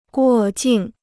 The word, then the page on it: 过境: guò jìng